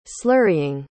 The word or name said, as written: slurrying